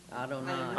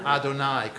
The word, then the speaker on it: Adonai: It is a name that is, when we read it in the Bible, how do we pronounce it? – Adonai. – Adonai, correctly.